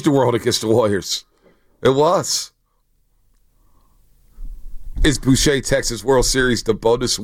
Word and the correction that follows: Bochy: It's Bochy. Oh, the Bochy. Bochy.